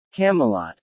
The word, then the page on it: Camelot: キャメロット